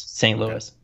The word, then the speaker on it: St. Louis: We're talking about Jonah Williams, Tyree St. Louis. I said that right, correctly? Correct? – Yes, St. Louis.